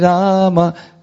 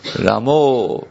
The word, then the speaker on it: Rama: Ramo, then you have joined the Bengalis. The Bengalis say always for A, they pronounce it as O.